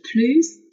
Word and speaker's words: plus: Si j'avais eu plus d'énergie, si j'avais eu plus d'énergie，我要是精力更充沛就好了。这个和我们刚才看到的那个 at 啊，它的用法是一样的，独立句当中的使用。那这里我需要说一下的是 plus 的这个 s 是要发音的，表示更多的什么什么哈。 plus d'énergie。